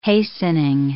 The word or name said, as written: hastening